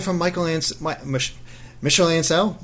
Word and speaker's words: Michel Ancel: M- M- Michel Ancel.